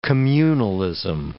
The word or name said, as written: communalism